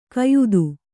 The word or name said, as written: ಕೆಯ್ದು